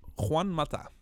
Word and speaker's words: Juan Mata: maar hoe spreek je de, de naam uit van die laatste voetballer dan? – Juan Mata.